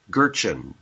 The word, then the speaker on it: Gertjan: Gertjan, GERTJAN.ca, Gertjan.ca.